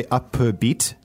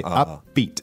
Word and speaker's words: upbeat: Yeah, so just pronunciation here, it's two words, it's a compound word, but we don't say upbeat, we say upbeat. – Upbeat, right. – The P and the B become like one sound.